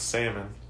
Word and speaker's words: salmon: Okay, see, you're saying that wrong for sure. It's salmon. – Salmon. – No, salmon. – How do you know what's right? – Because that's how it's said.